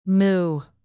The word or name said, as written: moo